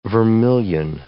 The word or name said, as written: vermilion